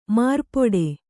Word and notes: ಮಾರ್ಪೊಡೆ: kannada; mārpoḍe

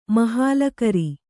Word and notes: ಮಹಾಲಕರಿ: mahālakari

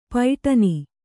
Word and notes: ಪೈಟನಿ: kannada

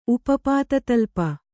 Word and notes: ಉಪಪಾತತಳ್ಪ: upa pāta kaḷpa

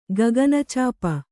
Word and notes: ಗಗನಚಾಪ: gagana cāpa

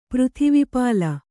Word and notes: ಪೃಥಿವೀಪಾಲ: kannada

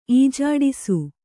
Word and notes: ಈಜಾಡಿಸು: Kannada

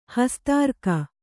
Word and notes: ಹಸ್ತಾರ್ಕ: hastārka

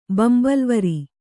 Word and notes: ಬಂಬಲ್ವರಿ: kannada

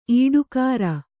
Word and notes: ಈಡುಕಾರ: Kannada

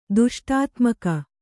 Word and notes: ದುಷ್ಟಾತ್ಮಕ: kannada

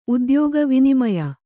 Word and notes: ಉದ್ಯೋಗ: udyōga

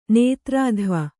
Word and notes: ನೇತೃತ್ವ: kannada

